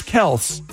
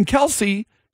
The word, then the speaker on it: Kelce: That it's not Kelce, it's Kelce. Okay. It's Kelce, but, and this isn't the first time that it's come up.